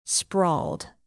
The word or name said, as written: sprawled